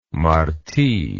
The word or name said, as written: marti